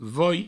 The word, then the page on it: voi: vouei